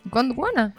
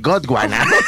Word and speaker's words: Gondwana: Gondwana, ¿no es tal cual? – está bien, está bien. Ajá. – Yo pensé que la gente por lo general dice eh, Godwana.